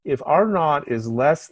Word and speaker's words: R naught: In um, understanding how infectious diseases spread in the population, there is a concept called R naught. It's a big R and a little sub zero, and it's pronounced in the British way R naught.